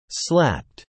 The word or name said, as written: slapped